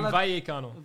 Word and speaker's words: Vallecano: He's not passing the Valakina Kanon. – You don't even know how to say his name. Vallecano.